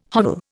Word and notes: hovel: Indian

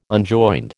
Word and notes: unjoined: American English

